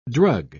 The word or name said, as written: drug